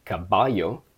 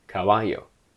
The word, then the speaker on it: caballo: They are not pronounced b, b, b, but a weaker form of it, which is o, o, o. So this word is not pronounced caballo, but caballo, caballo, caballo, which again could technically be spelt with a V based on the sounds, but that would be wrong.